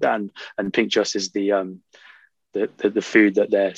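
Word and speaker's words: pintxos: Now, how do you pronounce that? Pinchos? – I believe it's Pinchos. That's a Basque country thing in Spain. Not that I'm any sort of expert on that, but I think that's how you pronounce it, Pinchos.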